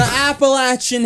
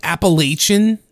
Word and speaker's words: Appalachian: Then they show up to the Constantina from the Appalachian Hills, where she is from. – I swear they had to say that wrong. Because isn't it like Appalachian? – Appalachian.